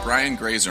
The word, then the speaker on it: Grazer: Ryan Grazer. Am I pronouncing your name correctly? – That's right. Rhymes with laser.